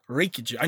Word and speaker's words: Reykjavik: Reykjavik.